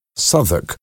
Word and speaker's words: Southwark: It was in an area called Southwark, pronounced Southwark, on the south bank of the River Thames, at the Globe Theatre.